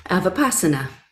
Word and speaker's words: Vipassana: And I don't know if this is the right way to say this, a Vipassana meditator? Vipassana? – Vipassana. – Ah, see, I knew I was wrong because I am always wrong with those words.